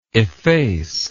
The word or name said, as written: effacer